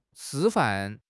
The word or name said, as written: 师范